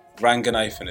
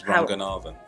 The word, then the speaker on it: Ranganathan: Ranganathan is not right. It's Ranganathan. – Oh. – That's the Sri Lankan pronunciation.